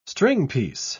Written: stringpiece

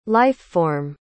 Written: life-form

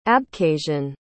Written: abkhazian

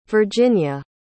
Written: virginia

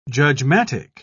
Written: judgmatic

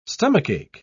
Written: stomachache

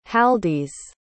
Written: haldis

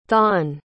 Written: thon

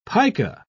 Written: pica

